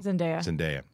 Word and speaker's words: Zendaya: And Zendaya, I always pronounce it wrong. – Zendaya.